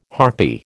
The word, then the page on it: harpy: American English